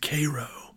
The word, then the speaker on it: Cairo: You know, like in Georgia, where I grew up, there's Cairo, like the city in Egypt, but they pronounce it Cairo.